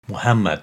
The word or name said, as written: Muhammad